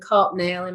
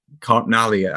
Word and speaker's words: Karpnale: I've always pronounced it Lipkin, Gorman, and Carpnail in my head. – I, mm. I did until I was corrected. Carpnali, I think he was an Italian gentleman.